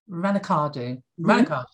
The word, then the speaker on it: Raducanu: Raducanu, that's the one.